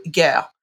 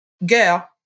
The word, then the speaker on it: Gueux: And the way that town is spelled is G-U-E-U-X. So if you didn't know how it was pronounced, you might call it Gueux. That would be not correct. And he includes a little phonetic pronunciation.